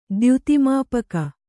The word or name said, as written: ದ್ಯುತಿಮಾಪಕ